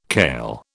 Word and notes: kail: British English

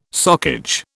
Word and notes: socage: British English

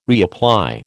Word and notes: reapply: American English

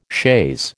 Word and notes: chaise: American English

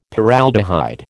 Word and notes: Paraldehyde: American English

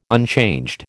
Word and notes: unchanged: American English

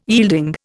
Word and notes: yielding: Indian

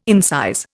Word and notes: incise: Indian

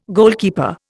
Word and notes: goalkeeper: Indian

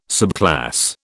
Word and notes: subclass: British English

